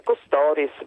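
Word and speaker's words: Kostoris: Dunque, innanzitutto ringrazio per dare al mio cognome oggi un connotato greco, perché il mio cognome in realtà in Italia si pronuncia Costoris, ma forse in Grecia Costoris, come dice Giannino.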